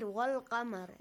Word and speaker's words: والقمر: Look, wal-qamari. We pronounce that law.